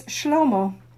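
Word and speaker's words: Shlomo (שלמה): The Hebrew pronunciation is Shlomo.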